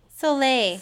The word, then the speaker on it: Soleil: All of them are so great, but Kid 90 with Solol Moonfry. My childhood. – Solol? – Wait, did I say it wrong? How do you say it? Soleil.